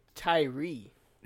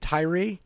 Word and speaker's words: Tyree: Variety has confirmed that Aubrey Plaza and Brian Trey. Am I saying that right? – Looks like Tyree. – Is it Tyree? – Yeah.